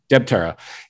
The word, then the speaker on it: Debtera: is it Debtera? – Debtera